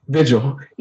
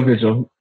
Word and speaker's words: Vigil: Vigil, Emilio Vigil. Yeah, just like that.